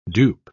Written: dupe

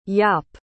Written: jaap

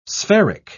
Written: spheric

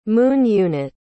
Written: Moon Unit

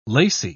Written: lacy

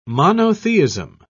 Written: monotheism

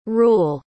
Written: ruel

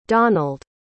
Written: donald

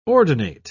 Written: ordinate

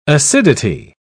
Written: acidity

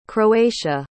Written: croatia

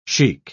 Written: sheikh

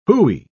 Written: hooey

